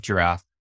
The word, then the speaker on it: Jirav: I'm the CEO and co-founder of Giraffe. It's a complete misspelling of the word giraffe. You just kind of lisp the B and the F together, but it's spelled J-I-R-A-V.